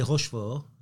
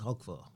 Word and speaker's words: Roquefort: So yeah, you don't say Rochefort, it's Roquefort.